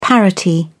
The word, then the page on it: parity: British English